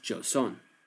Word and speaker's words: Joseon: Among the territory gained was a large portion of a peninsula to the northeast, whose people were called in China the Chaoxian, and pronounced by the tribal locals as Joseon.